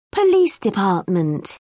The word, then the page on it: police department: British English